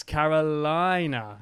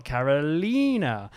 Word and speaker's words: Carolina: But she said, it's Carolina, not Carolina. It's named after my state, South Carolina.